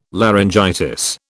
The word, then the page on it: laryngitis: British English